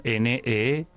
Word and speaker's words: Need Car Help: Need Car Help, que sería NEEDCARHELP. Sería necesito en inglés, coche en inglés, car y help en inglés. Ayuda. Need Car Help. ¿Lo he dicho bien? – Muy bien, Fernando.